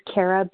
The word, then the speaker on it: Kara: My name is Kara B., spelled with a K. – K-A-R-A.